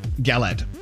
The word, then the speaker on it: galette: Is it pronounced galette? – Galette. – Galette. Mm-hmm.